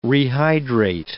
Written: rehydrate

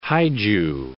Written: Haeju

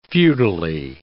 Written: feudally

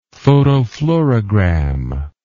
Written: photofluorogram

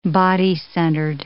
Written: body-centered

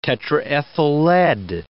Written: tetraethyl lead

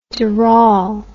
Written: doral

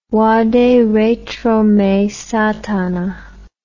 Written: vaderetromesatana

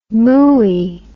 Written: mooey

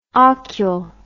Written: ocul

